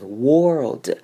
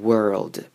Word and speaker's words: world: 很多人啊，我会听到会念成 world，把 o 发成 or 那个声音，但是其实呢，这边要发成像 er 端母音 er 的声音就好了，所以我们这个字会念成 world。